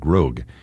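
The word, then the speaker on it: Grohg: This is from a very early composition by Copland, a ballet called Grog, G-R-O-H-G. I think that's how you pronounce it, not Grog, but Grog.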